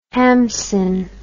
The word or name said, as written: hamsin